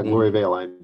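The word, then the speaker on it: Gloriavale: Yeah, Gloria Vale. I do believe so. I mean, you got to say with a New Zealand accent, Gloria Vale or something like that.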